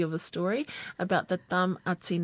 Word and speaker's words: Tamati: Whose surname can easily be mistaken for being Māori, where the pronunciation is Tamati, and in English means Thomas, but in Samoan is Tamati.